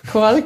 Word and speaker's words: carl: Karl, genau, ja. Wir hören, wir hören die verschiedensten ähm, Aussprachen und das macht auch eigentlich Spaß bei dem, bei dem Namen Karl, weil man das sozusagen auch in, in, international oder auch bis zum Call spielen kann.